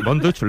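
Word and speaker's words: Von Dutch: Así que, Bon Dutch, ¿lo he pronunciado bien? – Sí, muy bien.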